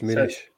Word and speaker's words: Muniz: That was an interesting pronunciation at the end there. – Munich, I like it. – He's Brazilian though, isn't he? It's Munich. – It's the harsh Munich.